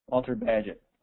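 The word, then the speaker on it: Bagehot: It's either Bagaho or Bagahot, but it's Walter. That's how you spell it. And so he had written a lot, Walt Badgett? Am I saying it right? Badgett? Walter Badgett? Danny's done. Okay, well, there you go. Walter Badgett.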